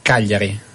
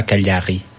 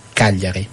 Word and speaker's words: Cagliari: Cagliari. Pas Cagliari. Cagliari. – Cagliari. Il y a une syllabe tonique sur le premier? Il n'y a rien?